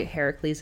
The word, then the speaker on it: Heracles: I did take Greek mythology in college, so I know a little bit of these, and I will say Heracles and not Hercules because that is how you say it.